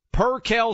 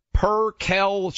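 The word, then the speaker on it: percale: We went back to MyPillow, and we said, hey, let's make sure we get this right. Per-Kel sheets, everyone out there.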